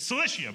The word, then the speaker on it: Cilicia: Well, I was like, Cilicia. It's Cilicia, by the way.